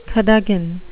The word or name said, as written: cadogan